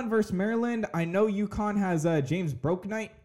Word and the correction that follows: Bouknight: James Bouknight. Bouknight.